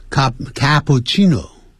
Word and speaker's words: cappuccino: He likes cappuccino, 也有念成 cappuccino, 啊，这是意大利话，所以两个 C 要念成气啊，这样气气的音 cappuccino, 啊 cappuccino, 或者有念成 cappuccino。